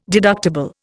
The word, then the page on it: deductible: Indian